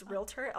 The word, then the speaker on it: realtor: But a real estate agent, a realtor, and it's so funny, this is a pet peeve of mine, and I'm so glad you pronounce it correctly, because it's realtor. A lot of people say realtor.